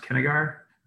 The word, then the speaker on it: Kinnegar: I think it's pronounced Kennegar. But the owner he's American. They make a killer saison. It's called White Rabbit. – That rings a bell too. – So it's pronounced. So it's spelled Kinnegar.